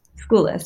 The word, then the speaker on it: Skoulas: Skoulas, yes.